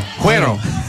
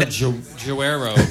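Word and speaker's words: Güero: Cuero. Cuero. All right, yeah. No G. No hard G. Cuero. – Okay. Fantastic. – Or Juero.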